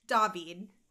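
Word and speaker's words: Daveed: Is it David? – David. – David. Okay. – Yep. Like French David.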